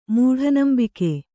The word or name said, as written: ಮೂಢನಂಬಿಕೆ